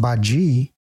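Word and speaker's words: Badi: Says, her name is pronounced Baji.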